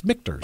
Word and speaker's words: Michter's: Yes, it's Michter's. – Okay. – Yeah, M-I-C-H, and that's where people get that messed up. It's Michter's? No, it's Michter's.